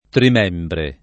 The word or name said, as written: trimembre